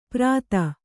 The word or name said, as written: ಪ್ರಾತ